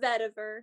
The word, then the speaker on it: vetiver: Is it vetiver? Is that how you pronounce it? – Vetiver. Yeah.